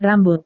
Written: rambut

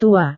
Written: tua

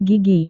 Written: gigi

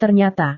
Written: ternyata

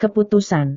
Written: keputusan

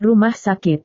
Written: rumah sakit

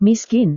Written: miskin

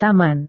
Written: taman

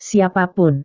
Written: siapa pun

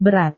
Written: berat

